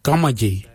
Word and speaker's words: gamadji: Jittery is spelled D-J-E-R-D-A-R-I, jittery, brown, jittery. And the last colour for green, we say gamaji. Gamaji for green. Gamaji is spelled G-A-M-A-D-J-I.